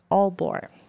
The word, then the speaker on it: Aalborg: Und da studierst du an der Royal Academy of Music in Denmark, Aalborg.